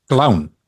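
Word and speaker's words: Klovn: En hij is gebaseerd op een Deense serie die heette Clown. Of Clown, maar dat spreek je uit als clown.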